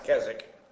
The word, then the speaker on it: Keswick: It's spelled Keswick, but it's pronounced Keswick.